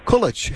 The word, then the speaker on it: Kulich: Joining me now is Suffolk University political science professor Christina Kulich. Have I pronounced, again, we get names. I hate getting names wrong. – Think about Kelvin. It's Kulich.